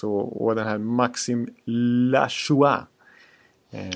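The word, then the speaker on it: Lajoie: Om man, om man, om man, om man skriver sitt namn Lajoje och vill ha det uttalat som Lachois, då får man skylla sig själv.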